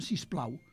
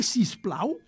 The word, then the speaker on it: sisplau: Perquè és molt natural que en català pronunciem sisplau. Mm. Dir sisplau és perfectament ben dit.